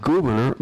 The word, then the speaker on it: Gouverneur: And today we want to talk about Gouverneur Morris. Gouverneur Morris. Now, that he wasn't spelled like a governor of a state. It was an unusual French spelling.